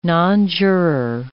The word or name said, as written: nonjuror